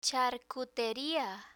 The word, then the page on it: charcuteria: spanish